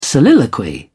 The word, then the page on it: soliloquy: British English